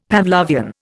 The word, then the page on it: Pavlovian: Indian